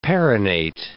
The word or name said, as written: perennate